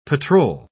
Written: patrol

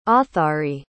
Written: authari

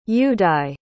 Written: Yudai

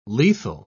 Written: lethal

